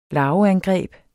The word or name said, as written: larveangreb